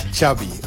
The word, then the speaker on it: Xavi: Xavi. – Ja. Okay. Xavi like chocolate. – Yes, oké. Okay. Thank you very much. – Nou, dat is tenminste duidelijk, toch? Yeah. Straks meer over die Xavi.